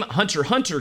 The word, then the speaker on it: Hunter x Hunter: And the name Hunter x Hunter came about with that silent X